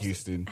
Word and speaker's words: Houston: That's spelled like Houston. – Houston. – Yes. – But it's spelled Houston, but we pronounce it Houston.